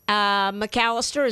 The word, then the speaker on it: McAllister: And my name is spelled a little weird, but M-A-R-C-I-A. McAllister is M-C capital A. L-L-I-S-T-E-R, and you'll find the post right there.